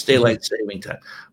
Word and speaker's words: daylight saving time: There's no S. It's daylight saving time, which I hate.